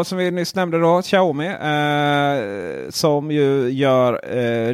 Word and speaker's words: Xiaomi: Xiaomi är det uttalas va? Xiaomi, och, ja. – Xiaomi. Ja.